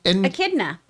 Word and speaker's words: echidna: At an investor's presentation on Tuesday, Paramount announced that the series focused on the red, I never know how to say this, and echidna. – Echidna. Mm-hmm. I always just want to say enchilada.